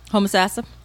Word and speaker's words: Homosassa: Mr. Libanati of Homosassa? – Homosassa. – Homosassa? – Yep. – Is that how you say that? Homosassa? Mm-hmm. – How the original inhabitants pronounce it, but that's how Florida pronounces it.